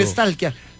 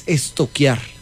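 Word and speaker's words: stalkear: Porque no se dice nunca estalquié, se dice estoquié. – ¿Estoquié? Sí. – Pregúntele a la profe, verá. A mí una vez estamos estoquiando. – Sí, estoqueando. Yo, ay, pero se escribe estalquear. Es estoquear.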